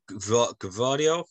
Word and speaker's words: Gvardiol: Yeah, Gvardiol, I think that's how you pronounce it.